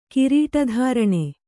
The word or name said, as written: ಕಿರೀಟಧಾರಣೆ